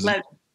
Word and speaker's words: Levy: Is it Levy? Is Levy. – Levy. See, bam, I already messed it up from the jump, but that's okay.